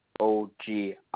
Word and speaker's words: Ogilvy: My first name is Dawson, Dawson, and my last name is Ogilvie, is Ogilvy.